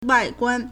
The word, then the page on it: 外观: wàiguān